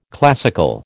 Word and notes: classical: American English

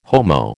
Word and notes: Homo: American English

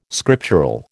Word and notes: scriptural: American English

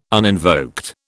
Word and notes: uninvoked: British English

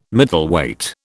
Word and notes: Middleweight: British English

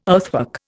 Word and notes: earthwork: Indian

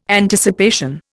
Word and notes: Anticipation: Indian

